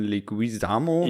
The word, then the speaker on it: Leguizamo: Ligui ja. – Lig ich kann den Namen nicht aussprechen, John Leguizamo.